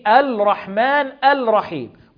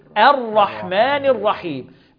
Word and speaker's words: الرحمن الرحيم: اللام في الرحمن والرحيم لام شمسية حكمها الإدغام، ما بننطقش اللام، ما بنقولش بسم الله، ما بنقولش الرحمن الرحيم، بل بنقول إيه؟ الرحمن الرحيم، بنحذف اللام ونشدد الحرف اللي بعدها، بنضغم اللام في الحرف اللي بعدها، لام شمسية حكمها الإيه؟